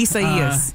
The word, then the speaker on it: Isaias: Tell us Isaias. A little bit more. Isaias. – Yes, which is not intuitive at all. I had to check the official National Hurricane Center pronunciation guide for that one because tough one.